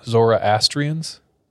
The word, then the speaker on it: Zoroastrians: Zoroastrians? – Sure.